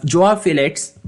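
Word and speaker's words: João Félix: Joao Felix.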